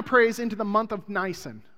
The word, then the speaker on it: Nisan: Now, that's pronounced Nisan, because I researched it. I had all kinds of Nissan jokes.